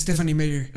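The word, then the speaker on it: Stephenie Meyer: ¿Stephanie Meyer? ¿Cómo se dice? – Stephanie Meyer.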